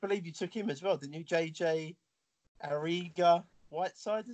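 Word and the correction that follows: Arcega-Whiteside: is it? Ariga? – I did take him. I'm just trying to find. JK Arcega Whiteside, I think it's pronounced.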